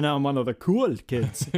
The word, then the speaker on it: cool: You should say, das ist cool. – Das ist cool. – Cool. – Cool. – You got to not pronounce it in the English way.